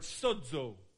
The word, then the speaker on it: sozo: It's a beautiful word, sozo. Would you say that? Sozo. Just say it like an Italian. Sozo. You know? It's not Italian, it's Greek.